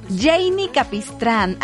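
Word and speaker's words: Jeinny Capistrán: Janie Capistrán.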